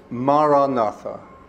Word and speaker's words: Maranatha: And this is the word Maranatha. Four syllables. Ma-ra-na-tha. Open vowel sound, calming sound.